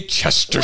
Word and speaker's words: Chesterton: Chesterton. – Way to go, Chesterson. – Satan's good. I gotta stop saying Chesterson because I'm going to forget how it's actually pronounced.